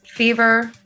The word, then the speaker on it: FEVR: Before that, you heard a favorite band of mine right now, Fever. It's spelled F-E-V-R, so it's missing one of the E's.